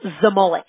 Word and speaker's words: Zmolek: How do you pronounce that? – Zmolek. – Zmolek. Okay. I thought so, but I don't like tearing up people's last names.